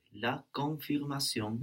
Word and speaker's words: la confirmation: And we have less N, no? On both of those N's, a lot less N. – Okay. La confirmation. – Very good. La confirmation. And also, not pronouncing that N kind of keeps the vowel in a different place in the mouth, which is much more French.